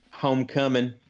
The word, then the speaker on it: Homecoming: Do not pronounce that G, sir. – Homecoming Kitchen.